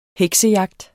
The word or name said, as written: heksejagt